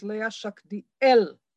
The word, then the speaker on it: Leah Schachtiel: And I will start by saying that my name is Leah Schachtiel. Difficult for Americans to put the stress on the last syllable, okay? So pretend you're French.